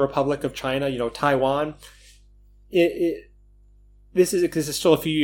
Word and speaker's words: Taiwan: Some of them got sent back to Taiwan. I said that very strangely. Got the emphasis on the wrong syllable. Some of them were sent back to Taiwan.